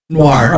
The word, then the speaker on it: noir: Film Noir, I think is how you say that. – Noir. – Okay, yeah. I knew I was saying the word wrong.